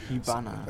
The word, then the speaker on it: Hibana: Wir sind auf Nippon Connection und wir haben uns gerade die erste Netflix japanische Produktion angeschaut, nämlich, wenn ich richtig aus Ausspreche, Hamibi oder Hanibi. – Hibana.